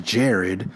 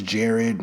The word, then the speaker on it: Jared: They're both named Jerry, and I hate the name Jerry, because when I say, hi, my name is Jared, a select group of douchebag dickheads like to say, Jerry? Your name's Jerry? No, my name's Jared, and I know I'm pronouncing it correctly.